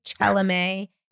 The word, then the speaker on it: Chalamet: Do you think Timothée Chalamet and thank you for using the correct pronunciation, because he will forever be a Victorian doll come to life.